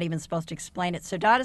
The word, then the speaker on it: saudade: Saudade.